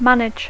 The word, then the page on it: manage: English